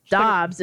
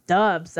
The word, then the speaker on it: Dobbs: I mean, yeah, I suppose you can say whatever But- it is, but if he starts coming out saying, no, it's not Dobbs, it's dubs. That'd be fun. – He actually had to do the opposite. So everyone kept every the initial when he got drafted, the initial pronunciation guide said dubs.